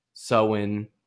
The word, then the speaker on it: Samhain: So modern day observance can be traced to the Celtic festival of, I would say, Samhain, but it's apparently pronounced Samhain or Samhain, a.k.a.